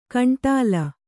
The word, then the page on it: ಗುಂಡಲ: kannada